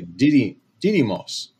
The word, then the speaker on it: Didymos: And so, of course, if this was an asteroid, um, by the way, the name of this asteroid is Didymos. D- I, I always pronounce it incorrectly. Didymos.